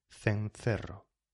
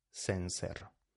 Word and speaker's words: cencerro: Esto también, ojo a la pronunciación, va con C, cencerro, diría alguien de la España peninsular, ¿sí? O de Baleares, Pero en el resto del mundo hispanohablante, los que se seamos, cencerro.